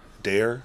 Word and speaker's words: Daher: I want to pronounce his name right. Mr. Mike... Dare. – Okay. Yeah, D-A-H-E-R. He's right.